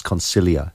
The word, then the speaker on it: Consiglio: But actually, the G is silent, so it's Consiglio.